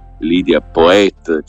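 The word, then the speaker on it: Lidia Poët: A Torino si poteva capire un po' di più perché eh, Lidia Poet, che come, come nome originario delle valli valdesi ha l'accento un po' francesizzante su, sulla E,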